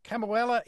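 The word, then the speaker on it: Kamuela: We're going to start with Chris, and Chris with us from Kamauela in Hawaii. Am I pronouncing that anywhere close to right? – You are correct, sir.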